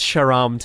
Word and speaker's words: Sharam: Yeah, no, it's it's Sharam today.